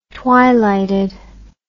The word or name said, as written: twilighted